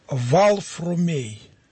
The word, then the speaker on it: Варфоломей: Так вот, когда он еще не был Сергием, а когда он был Варфоломеем, вот в то время на Руси имя Варфоломей не произносилось так, как произносится сейчас. Оно произносилось Валфрумей. Валфрумей. И если бы в древности кто-то бы искал этого отрока, и он говорил, мне нужен Варфоломей, он бы не нашел его.